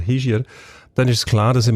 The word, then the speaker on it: Hischier: Wir haben den Vorzeige-Hockeyaner, den Ishier. Habe ich es richtig gesagt das mal? – Oder wie sagt man? Ich sage auch Ishier. Richtig muss man glaube ich sagen Hischier, weil er ein Oberwalliser ist.